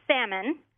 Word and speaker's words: salmon: It's salmon. – Okay. – I don't know why they decided to put an L in the word because you don't pronounce it. – It's a silent L.